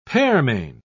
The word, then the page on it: pearmain: British English